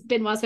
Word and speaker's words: Benoit: Benoit. – I've been mispronouncing it this whole week. Benoit Blanc.